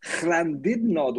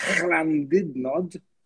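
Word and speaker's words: Llandudno: Das mag eine etwas übertriebene Aussprache sein, aber ich probiere es nochmal. Llandidnod Wales. Das ja, ist in, äh, bestes Waliser, ich glaube nur. – Aber das, das, das L, L ist das gutturale, glaube ich.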